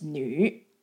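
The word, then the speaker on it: nu (女): So it's pronounced as fu. And the character of a woman pronounced as nu. So now the Chinese word for female in general, they say fu nu together.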